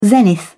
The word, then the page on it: zenith: British English